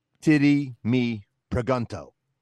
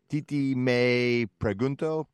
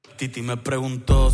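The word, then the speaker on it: Tití Me Preguntó: So in Ohio, they would say, titty me pregunto. – Right. – But that's not how it's pronounced, right? Because it's Spanish. So it's more like something like titi me pregunto.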